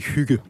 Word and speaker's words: hygge: How do you say hygge? – We say hygge.